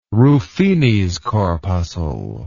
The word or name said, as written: Ruffini's corpuscle